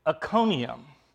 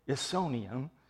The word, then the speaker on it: Iconium: They go to Iconium. Again, that C. Do not say Isonium. It's a hard C. Kappa.